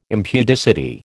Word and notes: impudicity: American English

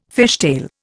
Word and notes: fishtail: Indian

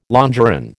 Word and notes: longeron: American English

